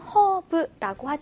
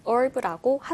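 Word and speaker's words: herb: 우리말로는 허브라고 하지만 영어 원어민들은 발음을 herb라고 하더라고요.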